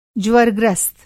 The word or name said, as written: ज्वरग्रस्त